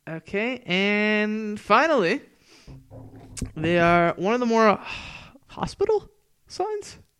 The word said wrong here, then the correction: hospitable: Am I saying that right? Hospital. – Okay. – Hospitable? – Hospitable. – No, H-O-S-P-I. – Yeah. – Table. Hospital. Hospitable.